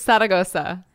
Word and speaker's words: Zaragoza: It's Zaragoza. – Zaragoza. – Yeah, okay. Perfect.